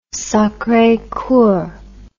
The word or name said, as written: Sacre Coeur